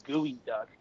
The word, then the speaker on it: geoduck: A gooey duck is a type of clam, but it's spelled like G-E-O-D-U-C-K, so it's like geoduck, but it's pronounced gooey duck.